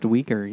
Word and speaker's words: Uyghur: Minorities that they have in the camps, the UIGH. – I think it's pronounced Uyghur.